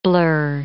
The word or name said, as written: blur